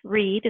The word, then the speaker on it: Reed: I would love for them to find me on Facebook, and it's under Martha Reed, and that's spelled R-E-E-D.